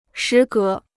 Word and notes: 时隔: shí gé

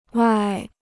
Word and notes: 外: wài

